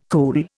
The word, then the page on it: cool: Indian